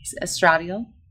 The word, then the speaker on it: estradiol: Is that how you say it? Estradiol? – Emily. Estradiol. – Estradiol.